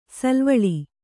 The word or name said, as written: ಸಾಲುವಳಿ